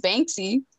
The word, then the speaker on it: Banksy: His name is Banksy. It runs with it, it rhymes with bank. sounds pretty close to bank.